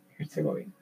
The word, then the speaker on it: Herzegovina: Se dice así, sí, Herzegovina.